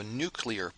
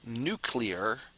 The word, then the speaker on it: nuclear: And real quick, take note that that's pronounced nuclear, not nuclear. Even though America has had several presidents that can't pronounce it correctly, it doesn't really matter how the president pronounces it. It's properly pronounced nuclear, and you can see that in the spelling there, nuclear.